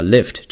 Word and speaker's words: Lyft: 到文章的第一句， Lyft, the ride-hailing company, has revealed that it is yet to make a profit ahead of an initial public offering which could value the firm at between $20 billion and $25 billion. – 这句话稍微有点长啊，没有关系，我们来看一下主语。第一个词叫 Lyft, 这是这家公司的名字。